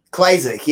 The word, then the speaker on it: Klyczek: Klyczek, yeah.